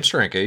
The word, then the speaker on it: Steranko: This is the Nick Fury story by Jim Steranko. – So you've got, yeah, Jim Steranko, you said it right.